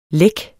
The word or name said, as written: læk